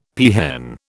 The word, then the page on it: peahen: British English